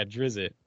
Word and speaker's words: Drizzt: It could be a what's his name that I can never say his name, Jurgen Drizzerzert. That guy's show. Drizzt. Yeah, Drizzt.